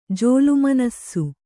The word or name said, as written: ಜೋಲುಮನಸ್ಸು